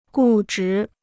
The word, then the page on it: 固执: gù zhí